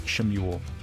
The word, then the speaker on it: Shmuel: Yep, we're in the book of 1 Shmuel. – That's good. I like that. Yeah, that's the Hebrew pronunciation.